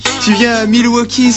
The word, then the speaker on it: Milwaukee: Ça se prononce Milwaukee.